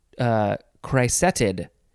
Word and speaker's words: cricetid: Next is criseted. Criseted, or you could say crisetted. Yeah, C-R-I-C-E-T-I-D.